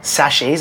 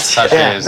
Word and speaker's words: sachets: And then now rolling out single serve sachets. Is that how you pronounce sachets. – It? – Sachets. – Sachets. Yeah.